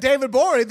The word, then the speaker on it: Gborie: David Gborie, the G is silent on Twitter.